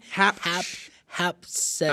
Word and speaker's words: Hatshepsut: Hatshepsut. I forgot already. – I got to look on this paper to read it again. It's so confusing. – Hatshepsut.